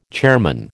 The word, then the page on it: chairman: American English